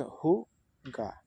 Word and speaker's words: Hygge: Hygge, pronounced Hugge, is a Danish concept that encapsulates coziness, contentment, and creating a warm atmosphere.